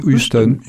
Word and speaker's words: Houston: Houston. Avec le H, oui. – On le souffle un peu.